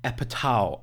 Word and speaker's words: Apatow: Was wir jetzt gerade noch äh, im Vorgespräch geklärt haben und jetzt ein für alle Mal äh, klarstellen, den Mann spricht man Apatow aus.